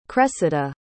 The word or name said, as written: cressida